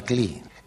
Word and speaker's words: Klee: In queste scene imparavo che c'erano Kandinsky e che Klee non si diceva Klee.